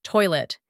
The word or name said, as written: toilet